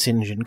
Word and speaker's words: St. John: That's how it's spelled, but it's pronounced St. John. Come on. – All right, Mr. St. John. – It's British.